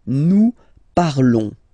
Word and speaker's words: parlons: Classical because you will see that with the other groups as well, it's quite common to have this O-N-S for nous, okay? Nous parlons, okay? Remember, final S is not pronounced, okay? And then this O N together, they will give you the sound on, on, okay?